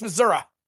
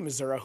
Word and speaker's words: Missouri: Independence, Missouri. – Ah, okay. – And I know it's pronounced like that because I have a friend from Kansas City, Missouri, who hates it when you call it Missouri.